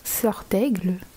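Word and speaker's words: Serdaigle: Hey, so the correct French pronunciation of the Hogwarts houses, we have Gryffindor, Ravenclaw, Sort d'Aigle, Hufflepuff, Pouf Souffle, and Slytherin, which is Serpentard.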